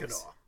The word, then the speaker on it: Mackinac: Mackinac City, which is nearby, changed the C to the W in their spelling so people could pronounce it correctly.